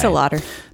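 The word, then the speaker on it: DeLaughter: It's not Laughter, right? Because it's spelled that way. No, it's DeLauder.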